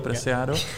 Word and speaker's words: Preciado: Presiado, já jsem si nastudoval, jak se to vyslovuje, Presiado.